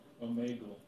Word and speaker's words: Omegle: How do you say this? Omegle? – Omegle.